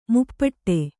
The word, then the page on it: ಮುಪ್ಪಟ್ಟೆ: muppaṭṭe